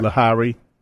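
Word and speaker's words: Lahiri: So I'll do the, how you say his name? – The Indian guy. – Lahari.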